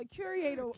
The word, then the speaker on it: curator: So a curator. – Curing the writer. – I'm not even pronouncing it right. Say it again. – Curator. – Curator. Is that right? – It's like curator. – Okay, got it now.